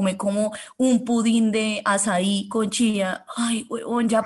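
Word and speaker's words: açaí: Y ahora en los desayunos se usan pudín de azaí. Se escribe acaí.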